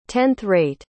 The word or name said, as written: tenth-rate